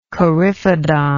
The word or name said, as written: coryphodon